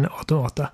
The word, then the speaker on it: Automata: eh, men du hade klarat ut eh, Near Automata. – Ja, eller som jag har lärt mig att man faktiskt uttalar det automata.